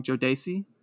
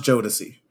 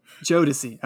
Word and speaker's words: Jodeci: correct me if I'm saying this wrong, Jodeci. – Oh my god. Okay. So that's pronounced Jodeci. – Jodeci.